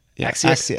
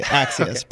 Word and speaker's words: Axios: What's the appropriate pronunciation? Axios? – Axios. – Axios. – Axios. Okay.